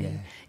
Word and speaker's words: Domenie: It's a title track off of Domenia, which is the name of your CD. – Yeah, how do you spell it? – D-O-M-E-N-I-E. – Okay, remember that. D-O-M-E-N-I-E. Domenia.